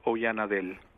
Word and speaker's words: Oyanadel: It's actually pretty close. My full name in English is pronounced Werner, and my last name is Oyanadel.